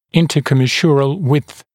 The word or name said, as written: intercommisural width